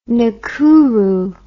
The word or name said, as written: Nakuru